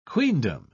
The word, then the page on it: queendom: British English